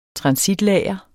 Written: transitlager

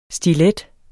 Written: stiletterne

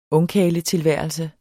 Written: ungkarletilværelse

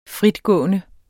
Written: fritgående

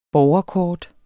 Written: borgerkort

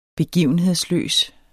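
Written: BEGIVENHEDSLØS

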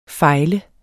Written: fejle

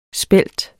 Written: spelt

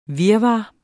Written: virvar

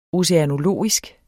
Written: oceanologisk

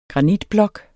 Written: granitblok

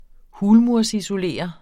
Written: hulmur

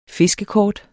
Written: fiskekort

